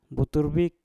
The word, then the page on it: butur bik: Mpur